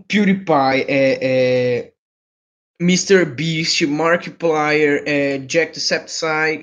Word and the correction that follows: Jacksepticeye: eu não sei pronunciar o, o, o nome do canal dele direito. É Jack, também. – Jacksepticeye. Só isso.